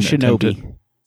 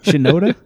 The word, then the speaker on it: Shinoda: I'm gonna just not even not Shinobi. Shinoda.